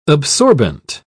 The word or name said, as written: absorbent